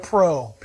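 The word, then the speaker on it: pro (πρό): But listen to this, in the Greek, in its original writing, it's the word pro, P-R-O. It's pronounced like that too.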